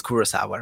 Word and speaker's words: Kurosawa: I think it's Kurosawa, right? – Yeah.